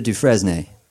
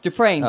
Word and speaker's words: Dufresne: Dear Mr. Dufresne. – It's Dufresne.